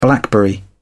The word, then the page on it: blackberry: British English